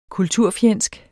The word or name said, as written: kulturfjendsk